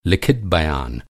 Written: बयान

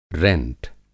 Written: रेंट

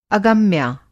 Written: अगम्या

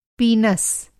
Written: पीनस

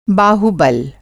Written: बाहु

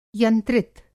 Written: यंत्रित

